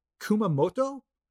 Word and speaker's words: Kumamoto: It's distributed by So Spirits and produced in Kumamoto.